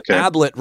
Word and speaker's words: Ablett: Ablett rhymes with tablet, yep.